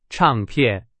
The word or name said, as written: 唱片